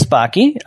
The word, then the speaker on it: Backe: Okay, well, my last name is Chris Backe, uh, rhymes with hockey or Rocky, and thank you for getting it right.